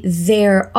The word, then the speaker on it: there: Notou a pronúncia do TH de there ali? É um TH vozeado, ou seja, ele produz uma vibraçãozinha na garganta. Você pode começar com o som de Z, assim.